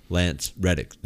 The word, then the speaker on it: Lance Reddick: James helped me out with the name Lance Reddick. Did I say it right that time? – Yes, I believe so.